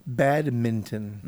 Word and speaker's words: badminton: Badminton. – Mm-hmm. – A lot of people don't pronounce that correctly. – Right. – They go right over that N.